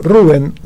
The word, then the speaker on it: Rubén: Bueno, hoy, el martes pasado, no pasó, fue el miércoles 26, desapareció físicamente el negro Rada, el negro Rubén Rada, sí, acento en la U, aunque sea, no sea verdadero, pero él dice que se llama Rubén Rada, y no había otra manera que hacerlo, que abrir este programa con él.